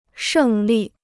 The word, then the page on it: 胜利: shèng lì